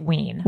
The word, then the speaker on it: Wein: Ween, W-E-I-N. Wendy Ween. – Because I had a relative whose last name was spelled like that, and they pronounced it Ween.